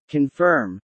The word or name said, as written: confirm